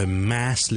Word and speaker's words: mass: mass，仲有一个啊音嚟读嘅，咁但系正确嘅发音咧系一个啊音嘅啊，即系我哋好似读 fat，fat，或 cat，cat，a fat cat，啊，咁咧挤落去 mass 呢个字度咧就系读成 mass。